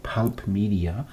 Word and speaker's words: Pulp Media: Pulp Media, also Englisch.